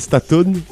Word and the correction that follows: Stanton: Staton. – C'était Stanton.